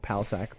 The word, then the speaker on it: Palascak: It's my last name is P-A-L-A-S-C-A-K. – Oh, yeah. – So it's just Michael Palasak. But that's not Michael Southside. Like Jordan, like Palasak.